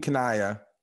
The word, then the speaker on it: Kanaya: I don't hate to Takumi Kanaya. Gosh, I can't say his name well. – Takumi Kanaya. Thank you.